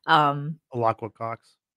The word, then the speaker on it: Alaqua Cox: Alacqua Cox.